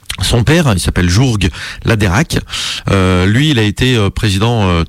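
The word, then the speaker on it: Läderach: Donc si vous allez en Suisse, bah n'achetez pas des chocolats qui seraient signés Laderach. Là, ça s'écrit Laderach. Voilà, je l'ai prononcé à la Suisse. Hein, je me suis entraîné un petit peu.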